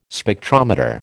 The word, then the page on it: Spectrometer: American English